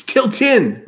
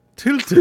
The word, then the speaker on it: Tilton: Kiltron, I was wondering where you were." – It's Tiltin. – Oh, sorry. Tiltin.